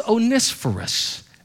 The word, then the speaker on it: Onesiphorus: His name is Onesiphorus. Onesiphorus. Some people say Onesiphorus. That's from the south. But the Greek pronunciation of it is Onesiphorus.